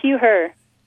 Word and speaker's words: Hugh Herr: Now, you also had the opportunity to work with Hugh Herr. Am I pronouncing his last name correctly? – Yeah, Hugh Herr.